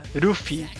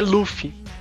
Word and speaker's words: Luffy: E R, porque não tem L no japonês, vocês já devem saber disso, então Luffy. Eles traduziram pra Ruffy. – Tanto é, é que na pronúncia deles, ele, eles falam, né? Ruffy! Ah! Aí não tem, não tem jeito, mas é Luffy.